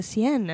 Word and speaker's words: Seine: How do you say the river there in Paris? – Oh, the Seine. – Seine.